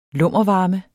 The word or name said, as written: lummervarme